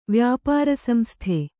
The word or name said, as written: ವ್ಯಾಪಾರಸಂಸ್ಥೆ